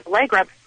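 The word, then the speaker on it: Ellygra: So my mom came up with an Allegra, spelled with an E, E-L-L-Y-G-R-A.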